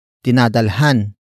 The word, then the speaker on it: dinadalhan: Word for word it's dinadalhan namin siya ng makakain.